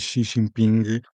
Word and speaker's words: Xi Jinping: Eu tenho aqui um pequeno para o Mac a dizer que a pronúncia não se diz Xi Jinping, mas sim Xi Jinping.